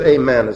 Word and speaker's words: amen: It is amen, as a matter of fact. Amen is how you say it in Greek.